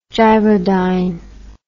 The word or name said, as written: gyrodyne